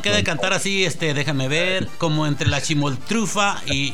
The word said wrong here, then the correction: Chimoltrufia: ¿O cómo se dice? Chimoltrufa. – Chimoltrufia. – Chimoltrufia.